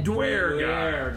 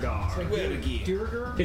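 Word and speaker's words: Duergar: And it's D-U-E-R-G-A-R. – Yeah. Dwergar. – Dwergar. – It's a Dwa with a D-U, not a Dwa with a D-W. Dwergar, not Dwergar. – Although, yeah. – Totally different. – I like Dwergar, though. It sounds better. – Dwergar. Like D, W-H-E-R-E.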